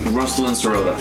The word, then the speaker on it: Ruslan Sirota: Ruslan Sirota. – As it looks on paper.